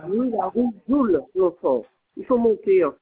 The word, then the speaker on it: Joule: Joule, J-O-U-L-E. L'enfant. – Joule. – Non, Joule, J-O-U-L-E. – Voule, d'accord. – J comme Julien, O comme Olivier. – Joule, d'accord. Et c'est quoi après Joule, rue Joule? – L'enfant. – L'enfant, d'accord. Donc L-O-N-G-F-O-R-T.